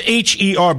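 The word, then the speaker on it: H.E.R.: The, the, guitarist Nick was alluding to is it's H E R, but it's pronounced. You say the word her. It's pronounced her, and um,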